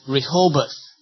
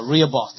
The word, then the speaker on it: Rehoboth: We called it Rehoboth, or Rehoboth, depending how you pronounce it.